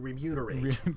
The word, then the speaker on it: remunerate: So we should all be careful to keep the mew in remunerate.